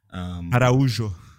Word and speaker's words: Araujo: I mean, I have to say though, when you look at, for example, Atlanta United, who brought in Araujo. I hope I said that right. Please correct me if I'm wrong. – Araujo. – Thank you.